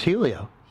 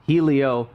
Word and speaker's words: Helio: It was Helio. – Helio Castroneves.